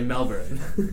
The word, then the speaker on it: Melbourne: But I took phonetics classes, and the R is there, so I'm going to stick with my American accent and say Melbourne.